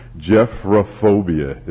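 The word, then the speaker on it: gephyrophobia: Jephrophobia is actually how you pronounce it.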